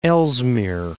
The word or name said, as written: Ellesmere Island